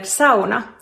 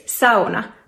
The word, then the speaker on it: sauna: Are you pronouncing the word sauna correctly in Finnish? It is sauna, not sauna or sauna.